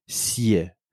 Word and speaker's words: Hsieh: And I'm sure my pronunciation is not good, because I'm pronouncing with a French-speaking accent. I will just spell the name because so that I'm sure I am okay. So you have Tony, T-O-N-Y, and his family name is H-S-I-E-H.